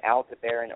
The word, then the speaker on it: Aldebaran: Aldebaran, depends on how you pronounce it, Aldebaran or Aldebaran, is at 9 degrees Gemini.